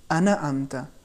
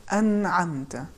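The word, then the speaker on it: أنعمت: مثال کے طور پر اگر کوئی شخص پڑھے انا امتا، یہ صحیح تلفظ نہیں ہے، چونکہ نون پر ہلکی سی زبر جیسی حرکت آ گئی ہے۔ صحیح تلفظ بالکل بغیر کسی حرکت کے ہیں، انعمتا۔ اسی طریقے سے نون کے بعد آواز رکنی بھی نہیں چاہیے، جیسے انعمتا، یہ بھی غلط ہے چونکہ آواز رک گئی ہے۔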